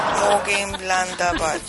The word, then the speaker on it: Mönchengladbach: Y el Manchester City visita Borussia Bach, Mönchengladbach, Mönchengladbach.